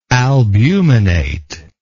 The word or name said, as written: albuminate